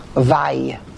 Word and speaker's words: Vav: The Vav, if you just take the Vav itself and you pronounce the Vav, we pronounce it as Vav, it's two Vavs. We're adding another letter. But Vav also can be pronounced Vay, Vav Yud.